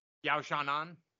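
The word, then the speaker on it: Yan Xiaonan: I don't say it as good as my co-host, who's going to say it right now. – Yao Chao Nan. – You see, he says it like he's living in China.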